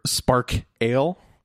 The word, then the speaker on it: Spark Ale: Their Spark Ale, like sparkle, but it's spelled Spark Ale.